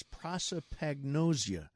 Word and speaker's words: prosopagnosia: So Brad Pitt, who is among America's most beloved actors, says that he suffers from something called face blindness, otherwise known as prosopagnosia. I think I actually said that right. – It sounded like you knew how to say it.